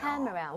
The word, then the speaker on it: camera: She bought me 여기에만 강세 주면 돼요. – camera. 오. – camera. 근데 진짜 무슨 이름절인 것처럼 라, 라 이렇게 돼야 돼요.